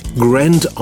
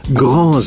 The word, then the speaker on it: Grand: Ich würde ihn amerikanisch Grand aussprechen, weil der Wagen schließlich den Namen einer amerikanischen Stadt trägt. Bei Hyundai hat man sich für die französische Aussprache entschieden und spricht vom Grand Santa Fe.